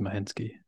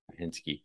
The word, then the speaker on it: Mashinsky: So yeah, sounds like Alex, how do you say his last name? Mashinsky. – I think it's just Mashinsky. – Okay. Mashinsky.